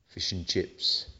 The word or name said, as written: fish n chips